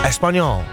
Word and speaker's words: Espanyol: That's how you say it, Eve. Espanyol.